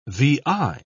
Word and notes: vi: British English

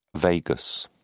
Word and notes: vagus: British English

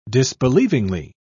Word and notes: disbelievingly: British English

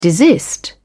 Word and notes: desist: British English

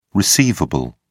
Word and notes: receivable: British English

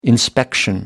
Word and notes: inspection: American English

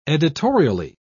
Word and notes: editorially: British English

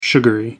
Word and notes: sugary: American English

